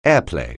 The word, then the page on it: airplay: English